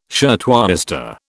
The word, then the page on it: shirtwaister: British English